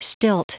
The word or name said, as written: stilt